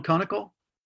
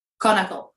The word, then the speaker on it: konnakol: Konnakol. Konnakol.